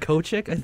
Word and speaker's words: Kochick: I don't even know how to say his last name. – It's like Dan Kochick.